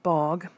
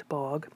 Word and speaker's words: Bauge: In 1994, Aud Bogg, I think that's how you pronounce it, it's spelled A-U-D, and then the last name is B-A-U-G-E, Aud Bogg, was evicted from her home in Netherland, Colorado for not having electricity and running water in her house, but still living there.